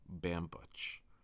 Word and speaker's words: Bambuch: I mean, I don't have to say my name a lot, but when I do, I just decide to go all American and say, it's Mike Bambuch. That just sounds really weird. I have no point to this, but I just think about that, and I say my name incorrectly all the time.